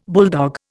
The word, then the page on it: bulldog: Indian